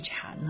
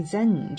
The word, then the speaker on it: 禅: 禅宗，禅在汉语当中的发音是禅啊，呃，在其他的时候呢，也有人把它译为 Zen 这个音，Zen 这个音。